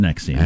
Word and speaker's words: NXIVM: well, it's spelled N-X-I-V-M, but it's pronounced Nexium.